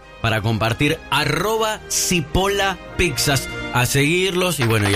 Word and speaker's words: Cipolla: Cipolla se escribe con C y con... con Elie, o sea, Cipolla. – Cipolla, ¿no? – Cipolla.